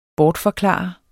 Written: BORTFORKLARET

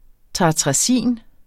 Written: tartrazin

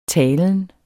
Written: talen

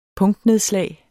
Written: punktnedslag

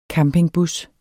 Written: campingbus

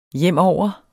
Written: hjemover

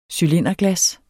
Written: cylinderglas

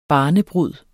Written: barnebrud

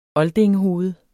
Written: oldingehoved

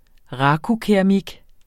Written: RAKU

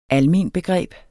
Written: almenbegreb

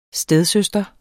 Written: stedsøster

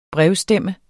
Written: brevstemme

